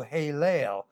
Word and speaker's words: Helel: His name actually is Halel, H-E-L-E-L, and the A-E, the E's there are pronounced as long A's. So Halel, and they come from the verb root halal, which in the original stem in Hebrew indicates praise.